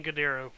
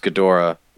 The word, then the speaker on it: Ghidorah: Yeah, I'm pretty sure I've heard it pronounced Ghidorah. – Look, or something like that.